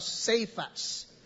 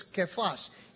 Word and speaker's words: Kephas: You will be called Kephas," or as what American preachers would pronounce, Cephas. In the way it's rendered in Greek, it's Kephas.